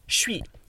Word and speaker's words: chuis: Um, but then sometimes this even gets respelled C H U I S, shui, um, because that's what it sounds like. The S at the end is silent because it's always silent.